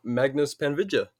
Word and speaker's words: Magnus Panvidya: And our special guest today is Magnus Panvidya.